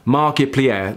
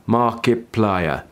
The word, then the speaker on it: Markiplier: Markiplier, like multiplier. Markiplier.